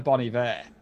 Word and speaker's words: Bon Iver: Like Justin Vernon from Bon Iver was on it, and if you listen to Bon Iver or Bon Iver as we pronounce it in Britain, don't think so.